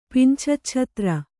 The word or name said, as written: ಪಿಂಛಚ್ಛತ್ರ